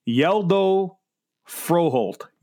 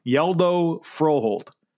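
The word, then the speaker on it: Hjalte Frøholdt: Here's how you say it. I have the pronunciation. It's Yeldo Froholt. Yeldo Froholt. That's from Denmark. That's how you pronounce it. Yeldo Froholt.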